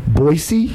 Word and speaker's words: Boise: It's my first time in what I learned how to say it correctly. Boise? – Yes. The S.